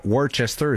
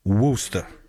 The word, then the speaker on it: Worcester: C'est pas Worcester, c'est Worcester. Ils prononcent ça de même.